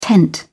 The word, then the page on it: tent: British English